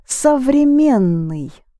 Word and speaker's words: современный: It's современный. Современный. With a different stress, and so there, as in с, with. Yes, so it becomes with time.